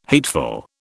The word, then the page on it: hateful: British English